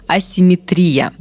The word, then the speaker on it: асимметрия: Но пока правильным вариантом является только с ударением на «и» – асимметрия.